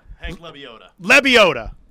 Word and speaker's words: Lebioda: Hank Lebioda.